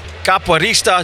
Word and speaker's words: capoeirista: We have a special guest here from the floor of New York Comic Con, actor, stuntman, capoeirista.